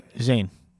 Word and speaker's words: Zayn: Zayn.